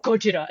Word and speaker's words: Gojira: And he's like, the name is Gojira. And then Gojira. Everyone else just says Godzilla with like no prompting, which I think is so funny. Like, they're like, Ken, you're Japanese. You need to say it like this.